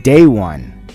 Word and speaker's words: De One: Oops, that's not the right pronunciation. Day One, with scratches and beat by K-Def. This was on the B-side to Day One's 94-95 single, True Homies. With the spelling of his name, D-E, that's why I pronounced it Day One, but it's actually pronounced Day One.